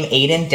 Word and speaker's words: Aydian: Yeah, so you can find me pretty much anywhere and everywhere that you go under the name Aiden Dowling. So A-Y-D-I-A. Wow, I spelled my own name wrong. So under the name Aiden Dowling, A-Y-D-I-A-N, Dowling, D-O-W-L-I-N-G.